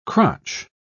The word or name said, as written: crotch